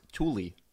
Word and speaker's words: Thule: I bought the Thule ones. They're white. – It's actually pronounced Thule. – Thule. Okay, good to know. – It is Swedish.